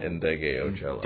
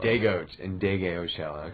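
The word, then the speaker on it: Ndegeocello: but Michelle Ngocello? – Ndege Ocello. – Ndege Ocello, okay.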